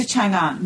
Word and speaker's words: Chang'an: Mei moves to Chang'an with her mother and her sisters. And did I say that correctly? – Yes, you okay. Got it right again.